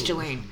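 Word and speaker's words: Ghislaine: the S isn't pronounced. I think it's Jelaine.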